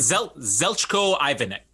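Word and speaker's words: Zeljko Ivanek: Zelchko Ivanic.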